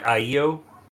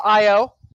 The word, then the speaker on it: IO: Isn't that more like IEO? – It's pronounced IO.